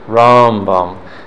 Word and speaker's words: Rambam: You'll be able to tell Rambam that it's in Mele'el, like the first paragraph, first syllable is accented. Rambam. And Ramban, it's the second syllable. Milara.